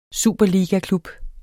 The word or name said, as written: superligaklub